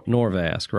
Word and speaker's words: Norvasc: And it's substituted for N O R V A S. – Nor- Norvasc, right? – Okay.